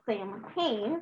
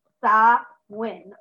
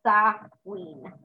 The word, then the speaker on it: Samhain: Though you're probably, if you look at it, tempted to pronounce it Samhain. – I used to pronounce it that way. – It's actually pronounced Sa-win or Sa-ween.